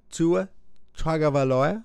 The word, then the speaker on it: Tua Tagovailoa: Tua Tagovailoa, did I say it right? – You nailed it.